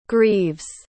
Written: grieves

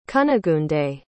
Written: cunigunde

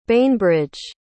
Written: bainbridge

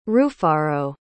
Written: rufaro

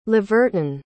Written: leverton

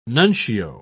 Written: nuncio

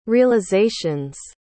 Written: realizations

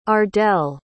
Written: ardelle

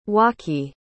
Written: waqi

